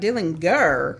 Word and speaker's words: Dillinger: I was surprised because he pronounced his name with a hard G, like Dillinger. Of course, I heard of him, but I stood there for a moment wondering if he knew how to say his own name. Well, of course, that's the way the family said it, and that was the right way.